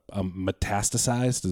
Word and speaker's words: metastasized: I always say this wrong, but metastasized, is that correct? – Yeah, you got it.